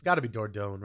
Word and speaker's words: Dordogne: I'm going with Dordogne. – I think you're correct. It is spelled D-O-R-D-O-G-N-E.